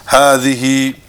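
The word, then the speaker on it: hadhihi (هذه): Hadha is pronounced as it were written with an alif, hadha, and hadhihi is pronounced as if it were written with an alif, hadhihi.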